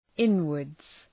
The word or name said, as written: inwards